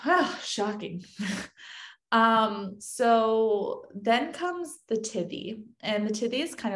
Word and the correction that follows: tithe: And oh, like a tithe. – Mm-hmm. Tithe, is that how you say it? – T-I-T-H-E. – Mm-hmm. Yep. Tithe.